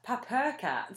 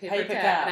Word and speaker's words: Paper Cat: Who, up until recently, both myself and Weasel pronounced Papur Cat, which I think is really cute. It's really cute. – Like a cat purse. – It's written that. It's like Papur Cat. – But we kind of heard someone in a video at Lexicon pronouncing it Paper Cat, and I had to break it to Gram Jams.